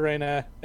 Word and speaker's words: Reyna: I wouldn't compare Reyna, and it is pronounced Reyna, canonically.